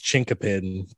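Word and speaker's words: chinquapin: It's pronounced Chinkapin, but it's spelled Chinkupin.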